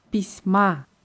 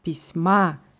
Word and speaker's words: письма: Im Genitiv heißt es Pisma. Hier ist wichtig die Betonung auf dem a. Pisma, das ist der Genitiv und was heißt Pisma? – Briefe im Plural.